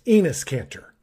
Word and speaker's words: Enes Kanter: I have to point out, number one, that is a UK player you're talking about from University of Kentucky, and it's pronounced Enes Kanter.